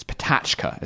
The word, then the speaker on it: Pecha Kucha: It's Patachka is how you pronounce it.